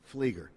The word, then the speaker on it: Fieger: Caleb Fieger, did I pronounce that correctly? – Fleeger. – What is it? – Fleeger. – Fleeger. He spells his name the wrong way to pronounce it.